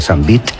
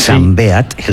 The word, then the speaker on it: Sambeat: Hablábamos de Perico Sambeat, que mucha gente se empeña en pronunciar Sambeat, sí. Sambeat, él es valenciano, y editasteis un disco, yo lo tengo por aquí.